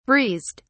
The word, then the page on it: breezed: British English